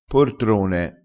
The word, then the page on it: portrone: dialettale